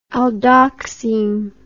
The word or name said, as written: aldoxime